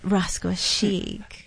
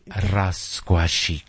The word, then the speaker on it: Rascuachic: You have to say it, Rascuachic. – You have to roll the R's. Rascuachic.